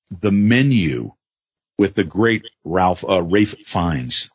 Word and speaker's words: Ralph: I almost said Ralph. Barry Rose, how awesome is this fucking movie? – Well, it is spelled Ralph, so you got to get it. – But it's pronounced Rafe.